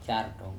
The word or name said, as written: cartON